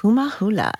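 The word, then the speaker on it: kumu hula: So did I say that right? Kuma hula? Is that how you say it? – Yes. – Kuma hula.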